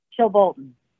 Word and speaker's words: Chilbolton: Chilbolton.